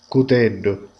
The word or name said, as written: coltello